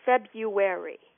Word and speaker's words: February: Those first R's tend to get elided in English pronunciation. That means they just get skipped over. We now know that the pronunciation February, without that first R pronounced, is the most common pronunciation of the word in English.